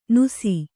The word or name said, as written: ನುಸಿ